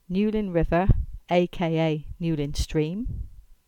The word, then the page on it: Newlyn River aka Newlyn Stream: English